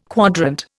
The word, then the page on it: quadrant: Indian